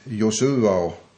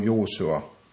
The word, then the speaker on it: Josua: Den här huvudpersonen, herr Josua eller Joshua, man brukar uttala det både Josua och Josua, och båda lär vara rätt.